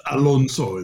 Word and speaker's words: Alonso: I did, but it's Alonso in Spanish, so you know it sounds different.